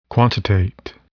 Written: quantitate

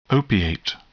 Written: opiate

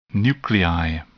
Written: nuclei

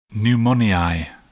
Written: pneumoniae

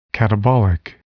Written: catabolic

